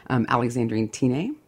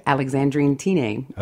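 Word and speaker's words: Alexandrine Tinne: Her name is Alexandrine Tine. She was a wealthy Dutch woman. – I'm going to ask you to spell that. – Sure. T-I-N-N-E is okay. Her last name. – Alexandria Tine? – Alexandrine Tine. – Okay.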